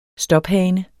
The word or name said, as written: stophane